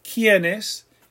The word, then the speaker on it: quiénes: And we talked about a diphthong before as well. ¿Quién? It's just like one syllable. That IE glides. ¿Quién? ¿Quiénes? ¿Quién? ¿Quiénes? ¿Quiénes has two syllables? ¿Quién? One. ¿Quiénes? Two. All right, let's look at our noun and pronouns.